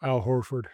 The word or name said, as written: Al Horford